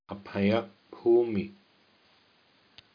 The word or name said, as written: apayabhumi